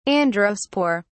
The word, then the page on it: androspore: British English